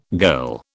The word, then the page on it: girl: British English